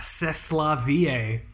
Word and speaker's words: c'est la vie: Ugh, c'est la vie, right? – That's exactly how you pronounce that.